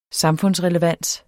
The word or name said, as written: samfundsrelevans